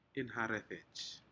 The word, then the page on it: inharedej: Aneityum